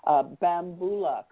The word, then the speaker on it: Bambula: Now, the song that I played to introduce you, Bambula, correct pronunciation? – That's correct. That's correct.